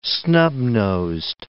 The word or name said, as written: snub-nosed